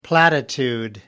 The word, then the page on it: platitude: American English